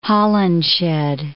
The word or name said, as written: Holinshed